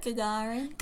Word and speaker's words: Gagarin: Gagarin.